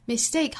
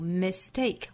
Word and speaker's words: mistake: Mistake 后面这个音节重读 mistake，或者是读成 mistake，第一个音节可以读成 a，也可以读成 a，是单词的两种读法。